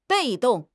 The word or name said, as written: 被动